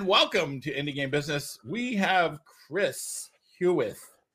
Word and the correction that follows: Hewish: who, did I say that right? Hewith? – Hewish, yep, you got Hewish. It. – Hewish, who is the big boss over here at Ixola.